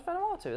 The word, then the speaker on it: Vanuatu: No, it's Vanuatu. That's how it's pronounced.